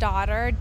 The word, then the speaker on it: DOTR: Yeah, the album is called Daughter, D-O-T-R, pronounced daughter.